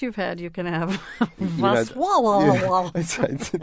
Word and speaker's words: Wrocław: Rocklaw. But anyway, it is pronounced sort of like this. Wrocław. Wrocław.